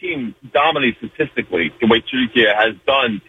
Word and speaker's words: Türkiye: The way Turkish people pronounce it is actually the way it's said Türkiye.